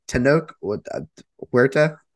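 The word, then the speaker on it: Tenoch Huerta: And at Tanoke, Huerta, did I say that right? Mm-hmm. Okay. As Namor, he did great.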